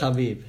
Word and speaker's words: Khabib: But obviously against Khabib, that wasn't shown too well because Khabib... is that how you say it? – Khabib.